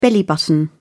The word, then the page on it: belly button: British English